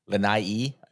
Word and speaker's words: Lanai: Well, I'd pick it up right there on just Maui in general, but our trip over to Lanai, Lanai, I believe is the proper pronunciation, was, and this will sound, you know, maybe an eye roll, but like highlight of the trip for me.